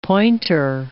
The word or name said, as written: pointer